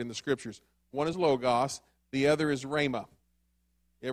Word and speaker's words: logos: So, that word, I hate to say it like that, that English word for word, the Greek is logos, L-O-G-O-S, logos.